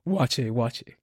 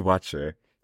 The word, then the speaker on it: waakye: It's spelled W-A-A-K-Y-E. Okay. That is the pronunciation. Okay, thank you. – Okay, you were wondering. – No clue how to pronounce it. – Wache. – What is wache?